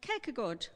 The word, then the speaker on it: Kierkegaard: But Kierkegaard, is that right? Yeah, reasonable. I speak like a Swede, not a Dane.